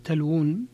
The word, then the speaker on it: تلوون: من ذلك أنه رسمت بواو واحدة وهي تنطق بواوين، وهذا معروف عند العرب من قبل، فإنهم يكتبون تلوون بواو واحدة وينطقونها بواوين، وهكذا رسمت في المصحف بواو واحدة، مع أن جميع القراء قرأها بواوين،